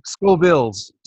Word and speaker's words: Scoville: So a million, what's it called? A million Scobies? A million? – Scovilles. Scovilles. – How do you spell it? S-C-O-V-I-L-E?